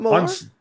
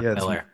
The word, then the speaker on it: Millar: Is it not Millar? – No. – It's Mark Miller. Yeah. – Oh, really? – Mm-hmm. – Yep. Man, I've been hearing it wrong my whole life. – Been saying it wrong.